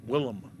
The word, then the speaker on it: Willem: William Dafoe as Mumra. – I recognize that name. – Yep. – Willem Dafoe.